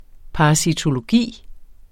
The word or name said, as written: parasitologi